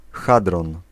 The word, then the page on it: hadron: /a.dʁɔ̃/; France (Île-de-France)